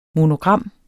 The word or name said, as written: monogram